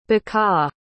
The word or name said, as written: bekaa